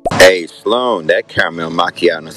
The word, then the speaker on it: macchiato: Macchiato. Not the H is silent.